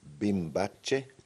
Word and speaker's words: Bimbache: How do you pronounce the name of the festival again? Sorry. Bimbache Open Art.